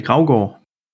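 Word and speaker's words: Gravgaard: Das ist, äh, wenn man sich so reinzieht, unseren Kader, wir hatten Frank Forst im Tor, Joris Mattheisen und, und hier Michael, Michael Grogard oder äh, Berger, wie spricht man den aus? Du bist doch Däne. – Michael Graugor. – Graugor, okay.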